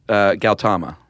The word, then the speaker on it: Gautama: Gautama.